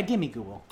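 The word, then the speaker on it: Gimmighoul: Gimmigool. – Mm-hmm. Is that how you pronounce that? – Yeah, Gimmigool. – I was wondering.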